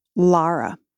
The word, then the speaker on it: Lara: My first thought is that my name is pronounced Lara.